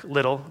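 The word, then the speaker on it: Liddell: Mark Wickersham talked about Eric Little, rhymes with fiddle, who was serving in Tianjin.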